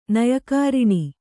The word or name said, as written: ನಯಕಾರಿಣಿ